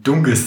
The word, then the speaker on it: Dunkes: Das schreibt man Dunkes. Dunkes. – Also D-U-N-K-E-S. E e Dunkes. Und es hat ein weiches K in der Mitte. – Das war. – Ein Dunges.